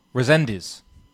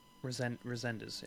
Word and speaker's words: Resendiz: Like Renez, I can't pronounce his name. Jose Armando Resendiz. – Resendiz, yeah. – Yeah, that was on the Canelo Charlo card.